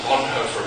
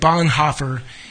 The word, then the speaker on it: Bonhoeffer: He pronounced it the German way. Dietrich Bonhoeffer is our wrong way of pronouncing that name, but that's how we know him.